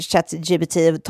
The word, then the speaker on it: ChatGPT: Bara det att på franska då alltså uttalar man ju det ChatGPT, vilket också låter exakt som när man som på katt. Franska säger eller? Säger katt jag pruttade. ChatGPT.